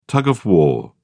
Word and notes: tug of war: British English

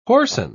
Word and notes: whoreson: British English

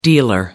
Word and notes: dealer: American English

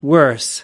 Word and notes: worse: American English

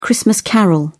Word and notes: christmas carol: British English

